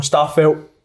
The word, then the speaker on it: Starfelt: Starfelt.